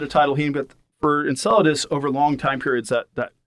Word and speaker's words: Enceladus: I believe it's pronounced Enceladus. – Enceladus, yeah.